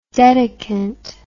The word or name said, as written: dedicant